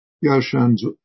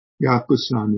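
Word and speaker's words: Yaoshan's (药山): Yaoshan's in my pronunciation of the Chinese, it's Yakusan in the Japanese way of pronouncing those characters.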